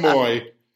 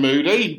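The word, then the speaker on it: Mooy: Yeah, Aaron Moody, boy. Moody boy. – You know, don't pronounce the D, you dingo.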